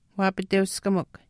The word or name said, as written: waapiteuskamikw